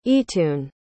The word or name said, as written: eatun